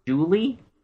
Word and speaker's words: Jewele: Duchess, Enchantress, Julie? – Yeah. – But it's spelled J-E-W-E-L-E.